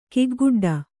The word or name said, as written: ಕಿಗ್ಗುಡ್ಡ